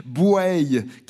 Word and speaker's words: Bouaye: Bouaye, car on dit bien ainsi, on prononce bien ainsi le nom de cette commune, le nom qui s'écrit B-O-U-A-Y-E, mais on dit Bouaye en général, une ville résidentielle de la métropole nantaise, ville de viticulture.